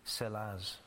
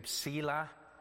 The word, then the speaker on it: Selah: Some people say Selah. That's fine, they're wrong. But if you were to pronounce it as an English word, it's Selah, but it's a short E in Hebrew.